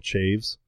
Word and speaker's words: Chaves: So, but this guy, I thought his name was Michael Chavez, but it's Michael, apparently pronounced Michael Chaves.